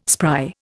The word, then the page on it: spry: Indian